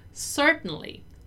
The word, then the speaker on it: certainly: Notice that this part here, the part from the middle of the word, is not really pronounced. It's like you eat it, right? Certainly, certainly, certainly, right? So you kind of eat that syllable and you don't really pronounce it.